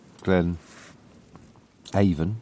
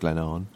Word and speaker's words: Glen Avon: And then had a rest there and started down Glen Avon, which is pronounced Glen Arn.